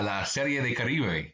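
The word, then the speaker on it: Serie del Caribe: um, being here in Mexico, it's actually pronounced uh, La Serie de Caribe.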